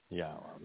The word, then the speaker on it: Yahweh: Yahweh. The proper pronunciation of Yod-Heh-Wah-Heh, our Eloah as God. He directed us to pronounce it this way based upon his Torah teaching regarding his Haya existence and our Shalom.